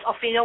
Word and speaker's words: Ophelia: My name is Ophelia Wong. – Oh, boy. Spell that. – O-P-H-E-L-I-A-W-O-N-G. W-O-N-G is my family name.